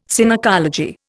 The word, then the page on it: Synecology: Indian